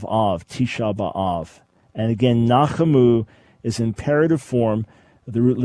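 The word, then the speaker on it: Nachamu (נחמו): Nachamu. Yeah, right. So just divide it into three. Na, and then the C-H-A, Cha, and then M-U, Mu. Nachamu, and the accent is on the last syllable.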